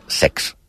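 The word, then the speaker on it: sechs: El 6 en alemany es pronuncia sex.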